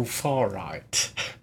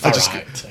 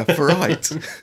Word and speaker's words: Farage: Nigel Farage. – That might be how it looks on the paper. – Oh, pronounced Farage. Okay. – Yeah, Farage.